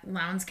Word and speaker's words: Lowndes: So, Kendrick Johnson was a 17-year-old student at Lowndes County High School. I believe it's Lowndes is how you pronounce it. It's L-O-W-N-D-E-S. Lowndes.